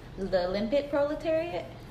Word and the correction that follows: lumpen: Lumpen proletariat, and then SpongeBob as the proletariat, and then Sandy as the bourgeoisie.